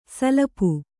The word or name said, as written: ಸಲಪು